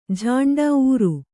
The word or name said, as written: ಊರು